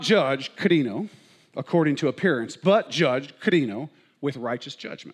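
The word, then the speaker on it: krino (κρίνω): Now, the word in Greek that's used for judge here is the word krino.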